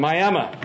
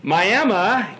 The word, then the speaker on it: Miami: I grew up in a small town in northeastern Oklahoma called Miami. Now, it's spelled M-I-A-M-I, just like Miami.